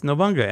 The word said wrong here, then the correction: Nobunaga: And I go back and look and I'm like, holy shit, it's Nobunaga. So probably about a year and a half ago, I realized that I've been saying that wrong for my entire life.